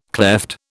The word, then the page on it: klepht: British English